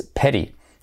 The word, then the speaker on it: pity: La pronunciación es pity, como acuérdate de pera, pera, pero con acabado en i, la primera e.